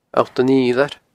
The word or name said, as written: Allt an Fhidhleir